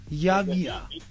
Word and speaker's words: Javier: Yavia. Okay, so no, I always want to learn. I'm a student. Every day I'm learning. So your name, I spell it J-A-Y-I-E-R. – J-A-V-I-E-R. – J-A-V, got you.